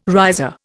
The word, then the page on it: riser: Indian